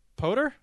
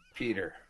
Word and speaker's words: Pyotr: Poter? – It's Peter. – Peter. Is that Peter? P-Y-O-T-R? – Yes.